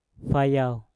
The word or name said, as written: fayau